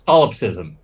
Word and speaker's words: solipsism: Slothism or sloth. Anyways, solipsism.